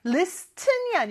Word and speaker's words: listen: listen이 아니고요. 여기는 발음은 listen. T 발음이 발음이 안 나요.